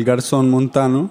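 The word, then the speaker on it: Garzón Montano: Multi-instrumentalist, producer, songwriter, Gabriel Garzon Montano. Am I saying that right? – Yeah, I mean, you could say Gabriel Garzon Montano.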